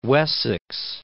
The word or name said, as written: Wessex